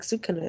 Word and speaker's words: Sukuna: and then the only reason that Sukuna, or Sukuna, I don't know. It sounds like they pronounce it like Sukuna in the show.